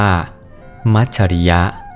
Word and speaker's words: มัจฉริยะ: มัชริยะมัชริยะแปลว่าความตรณีความเสียดายความหวงแหนมัชริยะ